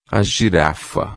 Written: girafa

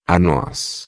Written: noz

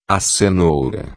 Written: cenoura

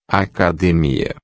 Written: academia

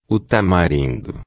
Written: tamarindo

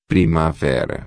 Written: primavera

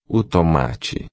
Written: tomate

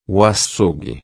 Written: acougue

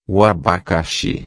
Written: abacaxi

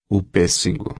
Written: pessego

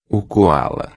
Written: coala

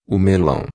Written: melao

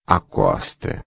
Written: costa

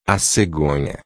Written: cegonha

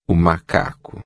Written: macaco

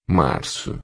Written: marco

